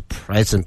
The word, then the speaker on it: present: 嗯，律师去呢，用的一个是 a lawyer present，这个发音一定要注意，是 present。